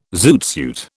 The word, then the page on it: zoot suit: British English